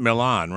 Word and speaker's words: Milan: Am I saying it right? It's not Milan, right? – It is Milan.